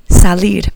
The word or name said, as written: salir